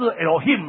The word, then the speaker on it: Elohim: 啊，上帝是什么，他们的一个希伯来文他的发音是 Elohim 嘛齁、哦、,Elohim。